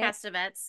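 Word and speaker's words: Castevet: He had his son Stephen Marcato, who turns out to be Roman Castavet. Is that how you say it? – Castavets, yeah.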